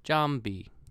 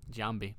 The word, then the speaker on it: Giambi: Or as um, Bob Sheppard would say, Jason Giambi. Giambi. Two syllables, not three.